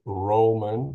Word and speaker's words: Rullman: Uh, he is the one and only Craig, Craig Rullman.